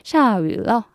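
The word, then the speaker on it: 下雨: 비는요, 위라고 발음하시면 됩니다. 위도 아니고 우도 아니고 그 중간 발음을 하시면 돼요. 그래서 샤윌러 하면 비가 온다라는 말이 됩니다.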